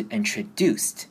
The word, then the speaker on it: introduced: introduced，任何英文字母只要后面是 s，在接 s 的声音结尾的动词，后面再变成过去式加 d 的时候呢，都要念成 st。所以这边是 introduced。